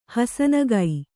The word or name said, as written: ಹುಸಿನಗೆ